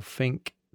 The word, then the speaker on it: Finke: Uh, after I saw some of your pictures, was that is it pronounced um, Finky or Fink? That desert race. – Fink. Fink Yeah.